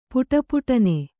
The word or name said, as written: ಪುಟಪುಟನೆ